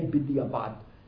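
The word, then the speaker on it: b'diavad (בדיעבד): Bidiyavad, Bidiyavad, again, you said I pronounced it Litvish, Bidiyavad. How do you really pronounce it? Bidiyavad. Bidiyavad is already Sephardic, but modern Hebrew, they say b'diavad.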